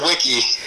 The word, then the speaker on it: Wiki: It's Wiki. – Wiki, Wiki, Wiki. Wiki. How do I spell that? Is that W-I? – W-I-K-I, like Wikipedia or WikiLeaks.